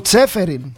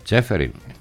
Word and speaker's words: Τσέφεριν: Ποιος, ο Τσφέ, δεν διαβάζει, τον μπορώ εγώ. Γυραιός, ο Σίλβα. – Ο Τσέφεριν. – Τσέφεριν, του είπε για την ΕΠΟ.